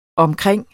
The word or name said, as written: omkring